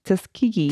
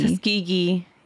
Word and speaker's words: Tuskegee: Last time I knew I was saying this wrong Tuskegee. – Tuskegee, yeah.